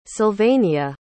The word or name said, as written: Sylvania